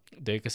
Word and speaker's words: Deika: Is it Dekai? It's spelled D-E-I-K-A. – Oh, I wrote it wrong then. – Yeah, I forget how they pronounce it Deka? In the show. Yeah, D-E-I-K-A, Deka.